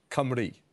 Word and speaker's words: Cymru: I had Senedd Simru. – No, the Senedd Cymru.